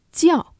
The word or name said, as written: 叫